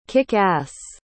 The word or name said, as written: kick-ass